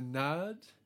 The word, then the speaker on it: Naad: We say nod, and we spell it with two A's to emphasize the ah sound, N-A-A-D. The nod. It's not the nat, it's the nod.